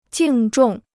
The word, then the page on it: 敬重: jìng zhòng